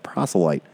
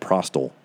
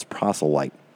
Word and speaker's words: proselyte: Proselyte. I think before I said prostal. It's proselyte. Not there's no T in there.